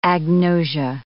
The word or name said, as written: agnosia